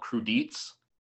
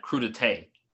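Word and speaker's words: crudités: I think me and my wife, we there's like a French dish, and I thought we thought it was called crudites, mm-hmm. – and then we were watching something on TV, and some French person said crudité. I was like, oh, crudite? That's how you pronounce it, crudité?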